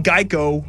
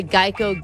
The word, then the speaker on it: Geico: The original idea was that a gecko could help people remember the name Geico, which often got pronounced like gecko.